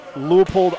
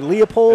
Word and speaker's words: Leupold: It's Leupold Optics. Am I saying that right? – It is Leupold, yes. – It's Leupold or Leopold? – It's not Leopold. It's okay. – Leupold.